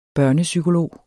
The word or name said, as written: børnepsykolog